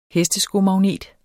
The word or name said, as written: hesteskomagnet